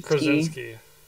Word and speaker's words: Krasinski: How do you say his name? Is it John Krasinski? – Krasinski.